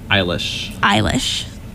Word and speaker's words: Eilish: Anyway, and then we had a song that I just started liking today, Bad Guy by Billie Eilish.